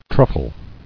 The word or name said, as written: Truffled